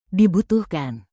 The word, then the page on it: dibutuhkan: indonesian